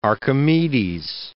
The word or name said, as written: Archimedes